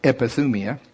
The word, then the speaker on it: epithumia: It's pronounced like it matters, epithumia.